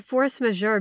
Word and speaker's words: Force Majeure: Well, Jared, you pronounced Force Majeure beautifully.